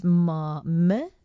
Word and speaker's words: Melania: It's Melania.